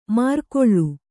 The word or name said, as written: ಮಾರ್ಕೊಳ್ಳು